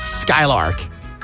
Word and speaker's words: SKYLRK: Og jeg er ret sikker på, at man skal udtale det Skylark. Det staves S-K-Y-L-R-K.